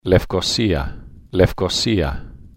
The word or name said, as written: lefkosia